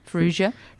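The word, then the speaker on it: Farrugia: I'd say it's soft G, yeah. – Yeah. – Soft G? – Ferrugia. Ferrugia.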